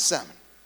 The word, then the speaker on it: Salmon: But in Matthew 1 verse 5, there's a man's name in Hebrew, S-A-L-M-O-N, the L is not silent.